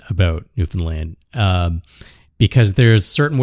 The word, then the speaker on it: Newfoundland: When you emphasize the found, like Newfoundland. – Yeah. – Or when you pronounce it more like Finland, you know, like the country Newfoundland. It's like, it's not Newfoundland, it's Newfoundland, you know.